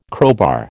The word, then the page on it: crowbar: American English